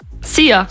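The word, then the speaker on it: szia: Hallo und Tschüss, könnt ihr euch ganz easy merken, wird einfach ausgesprochen wie unser Energy-Star Sia. – Sia.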